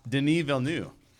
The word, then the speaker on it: Denis Villeneuve: Denis Villeneuve is who I'm picking. – Ooh, and you learned how to pronounce his name. Good job.